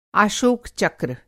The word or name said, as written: आशोक-चक्र